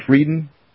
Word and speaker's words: Frieden: Tom Friedan, is that the guy's name, Frieden? Thomas Friedan? How do you say his name? – I say Frieden. – You say Frieden? Yeah, it's probably Frieden.